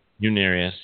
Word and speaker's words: Unarius: Unarius, yep. UNARIUS, Unarius.